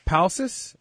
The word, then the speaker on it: Palcis: So the next question comes from Mitch Palcis. Am I saying that right? P-A-L-C-I-S? – That's how I pronounce it.